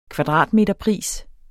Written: kvadratmeterpris

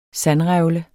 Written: sandrevle

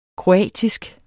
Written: kroatisk

